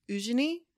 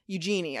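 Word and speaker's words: Eugénie: E-U-G-E-I. No, it's Eugene, except with an I in the at the end. Eugenie, I think is how you would say it in English, but it's French.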